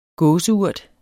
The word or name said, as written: gåseurt